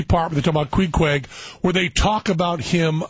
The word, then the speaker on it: Queequeg: Queequeg is how you pronounce that bizarre symbol.